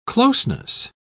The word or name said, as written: closeness